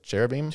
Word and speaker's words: cherubim: Like in Genesis, it said the cherubim. Right. How do you pronounce it? Cherubim? – Cherubim. It's good.